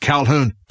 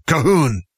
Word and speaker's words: Calhoun: And they looked at me and shook their head and they said, no, not Calhoun, Cahoon. And so that's my Gaelic name is Calhoun. And it's even spelled much different. You don't even want to know the Gaelic spelling. But that's how they pronounced it, was Calhoun.